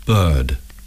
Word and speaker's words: bird: Bird, bird, hurt, hurt. – Some speakers of British English do not sound the r in this word.